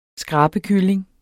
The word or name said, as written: skrabekylling